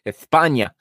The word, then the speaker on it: España: For España, I just like that Spanish pronunciation there, just like they do in Spain. España, they have they've got Unai Simón in goal. They're playing 4-3-3.